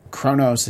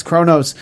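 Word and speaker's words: Qo'noS: And so from that is basically where we get this idea that they're going to attack the Klingon homeworld, which is Kronos. It's spelled Quonos. I had the closed captioning on when I was watching it, so it's with the Q, and but it's Kronos.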